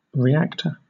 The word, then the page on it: reactor: British English